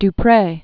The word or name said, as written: du Pre, Jacqueline